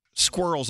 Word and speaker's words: squirrels: You sound like you're doing it correctly. Squirrels, like one syllable.